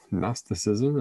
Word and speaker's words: Gnosticism: The G is silent, so it looks like Gnosticism, but it's Gnosticism.